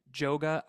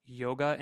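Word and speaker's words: Jóga: It is written Joga. I believe it may be pronounced Yoga, and it is about, I think, uh, her best friend at the time, a beautiful song.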